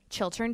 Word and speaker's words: Chiltern: Well, tell me about Chiltern. That's how you say it, right? – Chiltern Firehouse.